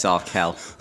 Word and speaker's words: Arkell: Hello, I'm going to start by being grumpy by telling you how to pronounce my name correctly. It's Arkell.